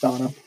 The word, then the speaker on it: Dana: I think it's Donna. Yeah, it's it's Donna. It's spelled Dana, but it's pronounced Donna.